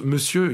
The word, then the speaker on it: monsieur: imaginez un mot comme ça qui s'écrit M-O-N-S-I-E-R et qu'on prononce monsieur. Il n'y a quasiment aucune lettre qui se prononce normalement.